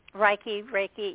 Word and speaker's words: Reiki: Or Reiki, I'm not mispronouncing that. – Reiki, yeah.